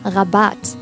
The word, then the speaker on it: Rabatt: 那这个单词的重音在后边这个 r 上，念作 Rabatt。